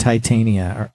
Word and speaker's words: Titania: So Merriam-Webster has three different pronunciations, and they sound like they're all right. So you're right either way. – All right. I won. – All right. Hey. – Titania, and Titania are all on Merriam-Webster.